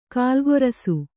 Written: ಕಾಲ್ಗೊರಸು

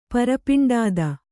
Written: ಪರಪಿಂಡಾದ

